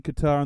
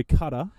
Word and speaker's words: Qatar: So a big story in Qatar in the early 70s. Well, sorry, Qatar. Qatar, as it's pronounced online as well now.